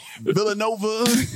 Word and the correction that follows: Villeneuve: Villeneuve. That's what it is.